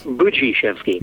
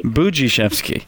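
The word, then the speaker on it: Budziszewski: Buczyszewski. Buczyszewski. It looks. I know you have to almost ignore how it's spelled unless you unless you've grown up speaking Polish.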